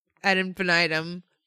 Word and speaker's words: ad infinitum: Infinitum? Is that how you pronounce it? – Ad infinitum. – Oh. Well, I've been doing that wrong for 36 years.